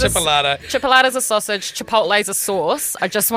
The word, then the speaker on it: Chipotle: It's pronounced Chipotle. Was that it? No. – Yeah, it's Chipotle. – Chipotle, yeah. – It's not Chipolata. It's Chipotle, and they're two different things.